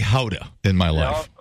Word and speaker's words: Gouda: It's not pronounced Gouda. It's Howda.